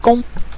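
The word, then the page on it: gong: tone 5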